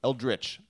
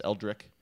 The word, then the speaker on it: Eldritch: Eldritch. I've been told that I say it wrong as Eldric.